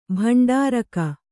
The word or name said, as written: ಭಂಡಾರಿಗ